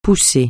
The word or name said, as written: poussé